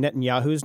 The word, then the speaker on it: Netanyahu: Before we leave, Brian, last week you were having a little trouble pronouncing Netanyahu's name.